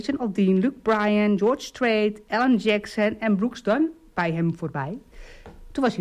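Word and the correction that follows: Brooks & Dunn: Brooks Dunn of Brooks Dunn? – Brooks en Dunn, sorry. Klemtoon. Okay.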